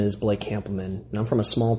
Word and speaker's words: Hampleman: I got really confused as to how to say his name because he sent me the message and he said, Hample, like you pronounce sample. Of course, Americans say sample and we say sample. So I think, oh no, it's Hampelman. But apparently it's not. It's Blake Hampelman, who's a teacher in Illinois, who I think has come up with a really interesting selection.